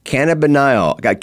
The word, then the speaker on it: cannabinol: Okay, and CBN, its medical term is cannabinol. Cannabinol is the right way to say that.